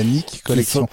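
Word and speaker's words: Nik Collection: Alors c'est un coup de cœur d'un produit qui s'appelle la Nick Collection et qui est sorti mercredi dernier en... Non. – La Nick Collection. Ça s'écrit N-I-K. Hein. – N-I-K Collection, ce n'est pas un truc de Don Juan, je t'arrête non.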